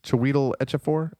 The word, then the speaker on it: Chiwetel Ejiofor: Did I say that right? – Say it again. – Chiwetel Ejiofor. Ejiofor. – Yeah, that sounds right.